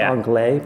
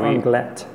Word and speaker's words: Anglet: We were saying it wrong. We thought it was Anglet, but it's Anglet.